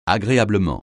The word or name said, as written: agréablement